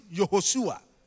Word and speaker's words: Yehoshua: So, Jesus, the Hebrew name is Joshua, and they pronounce it Yehoshua.